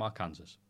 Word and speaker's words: Arkansas: Colton from Arkansas. – Arkansas. – Oh, not their Kansas. Our Kansas. Not Arkansas. – Is that how you spell Arkansas? – Arkansas? – Yeah. – The S is silent.